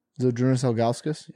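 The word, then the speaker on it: Žydrūnas Ilgauskas: How accurate is that pronunciation? – Zydrunas Ilgauskas? – Yeah. – That one's very accurate.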